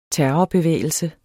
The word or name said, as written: terrorbevægelse